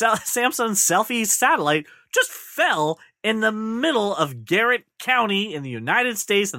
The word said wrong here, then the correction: Gratiot: Gratiot County.